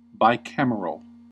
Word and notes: bicameral: American English

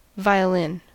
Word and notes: violin: American English

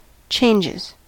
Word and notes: changes: American English

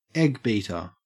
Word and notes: eggbeater: Australian English

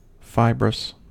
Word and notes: fibrous: American English

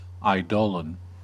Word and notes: eidolon: American English

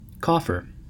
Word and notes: coffer: American English

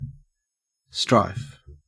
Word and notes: strife: Australian English

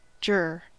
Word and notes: juror: American English

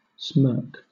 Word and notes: smirk: British English

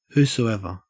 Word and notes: whosoever: Australian English

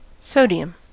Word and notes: sodium: American English

